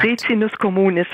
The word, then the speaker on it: Ricinus communis: Nun hatten Sie schon beschrieben, es kommt in der Pflanze, ich hoffe, ich habe mir es richtig gemerkt, Ricinus communis, habe ich das richtig gesagt? – Ricinus communis heißt der.